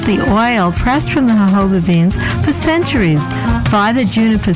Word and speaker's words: jojoba: This edition of The Natural Medicine Chest will discuss jojoba, the miracle plant of the desert. – Jojoba is spelled J-O-J-O-B-A, but is pronounced according to its Spanish origins.